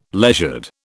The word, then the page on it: leisured: British English